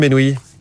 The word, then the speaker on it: Benouis: Bonjour Wassim Benoui. Bonjour. Bienvenue sur Radio Classique. Vous êtes le cofondateur de Studiava. J'ai un doute sur la prononciation de votre nom. C'est parfait. C'est parfait, parfait.